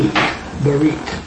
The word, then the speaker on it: berit: So to give you an idea of what covenant means, and it's the Hebrew word barit, used 264 times. – Will you spell it, please? – Yeah. B-E-R-I-T, barit.